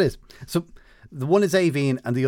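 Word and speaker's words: Aoibhinn: Which is A-O-I-B-H as well, but it ends with E-A-N-N. And the first one of those is pronounced Avine, so you were close.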